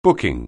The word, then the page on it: booking: anglais